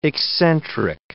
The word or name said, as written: eccentric